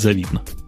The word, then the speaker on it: завидно: По-моему, схема достаточно хорошая, достаточно понятная, и даже где-то мне немножко, наверное, завидно. Или правильно говорить, завидно.